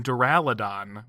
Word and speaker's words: Duraludon: So I think Snorlax is back, and Duraludon,